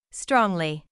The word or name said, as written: strongly